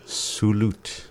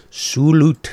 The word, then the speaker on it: Sulut: How do you say channel again? – Sulut. – Sulut.